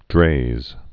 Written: Draize test

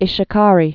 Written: Ishikari Bay

